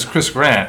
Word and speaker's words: Chris Grant: My name is Chris Grant, and it rhymes with Chris Plant.